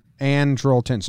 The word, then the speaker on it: Andrelton: Speaking of defense, Andrelton Simmons. Andrelton? How do you say his first name? – Andrelton. – So I nailed it, and then I double-checked it.